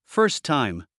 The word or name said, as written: first time